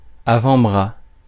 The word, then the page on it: avant-bras: France; [a.vɑ̃.bʁa]